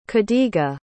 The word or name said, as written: khadiga